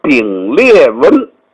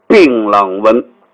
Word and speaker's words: 槟榔纹: 叫丙裂纹。哎呦，我说这个词儿怎么传下来的？哎，就是他根据这个词句的发音，又因为他又不懂，他把它叫成丙裂纹。其实这个东西不叫丙裂纹，它的发音和丙裂纹近似，这个纹路叫槟榔纹。